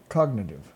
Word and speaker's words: cognitive: Congenitive, is that right? – Cognitive.